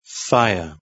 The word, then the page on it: fire: British English